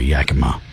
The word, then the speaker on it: Yakima: Oh, that's how you say it. – I'm sorry, Yakima.